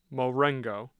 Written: Marengo

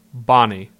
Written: Bonnie

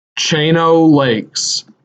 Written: Chain O’ Lakes

